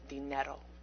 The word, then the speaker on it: De Niro: And then my husband, he goes, you're not saying it right. It's De Niro.